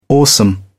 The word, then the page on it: awesome: British English